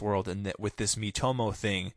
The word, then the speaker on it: Miitomo: Miitomo? – Miitomo, yes. – M-I-I-T-O-M-O. – Yeah, so if it's pronounced like the Miis were on the Wii, Yeah.